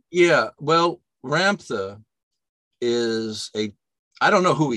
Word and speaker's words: Ramtha: 1990, you enrolled in the Ramtha, am I saying that correctly? – Correct, yeah.